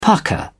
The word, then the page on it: pucker: British English